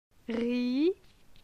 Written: Ri

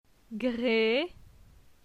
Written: Gre

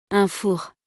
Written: un four

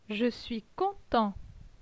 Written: Je suis content

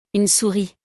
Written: Une souris

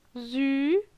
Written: Zu

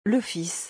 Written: Le fils

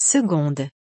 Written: 2de

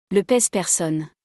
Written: Le pese-personne

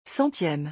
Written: 100eme

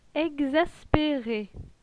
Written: Exaspere

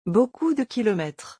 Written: beaucoup de kilomètres

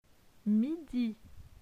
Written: Midi

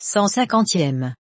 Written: 150eme

